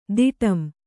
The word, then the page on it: ತಡಂ: Kannada